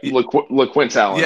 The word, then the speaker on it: LeQuint Allen: And it's so funny, as we were talking about the what's going on with, I believe it was LeQuint Allen is the running back. Am I saying that right? – Yep, LeQuint Allen.